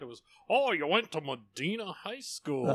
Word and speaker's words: Medina: Well, if you're a hillbilly from Ohio, it's Medina. – Medina. – All right, that's what we'll go with. – If you're from Texas, they say Medina.